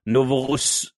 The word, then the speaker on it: Novorossiysk: It was it's like Novoryask or something. Let me bring it up. I definitely butchered that. – Let's see here. – Everyone's going to be tutting at me now. – I'm not sure if it's in Vicky yet. No. Oh, there it is. Novorossiysk. I'll copy paste it into chat. There you go. Oh, wait. That's how you say it.